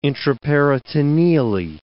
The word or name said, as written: intraperitoneally